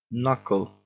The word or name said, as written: knuckle